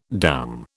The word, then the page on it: doum: British English